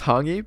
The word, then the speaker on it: hangi: Oh, it's pronounced hangi, but I think, but yeah. It's spelled H-A-N-G-I.